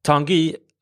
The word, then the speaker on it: Tanguy: Men man har sålt Tongai Ndombele till Tottenham. – Tanguy. – Ja, du kan få ta det uttalet här. – Nej, jag vet bara att det är Tanguy Ndombele.